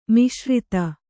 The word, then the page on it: ಮಿಶ್ರಿತ: kannada